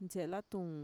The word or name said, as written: ntxe'elatuun